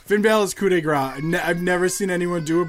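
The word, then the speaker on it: coup de grâce: so my number one currently, Finn Balor's coup de grace. One of my favorites. Did I say that right? I said that. – Yeah, right. – you said that right. Coup de grace.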